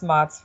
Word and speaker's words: Matz: So yeah, it's Matz for the record. – Nice, it's a German name.